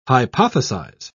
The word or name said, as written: hypothesize